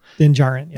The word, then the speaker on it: Djarin: Yeah, I said it wrong. You're welcome. – Din Djarin. – Yeah. I know. It's played by Pedro Pascal. The D is silent.